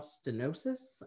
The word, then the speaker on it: stenosis: um, so you were diagnosed with spinal stenosis.